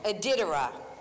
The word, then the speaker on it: Iditarod: Iditarod.